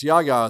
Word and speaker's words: Yahya: It's Yaya is his first name.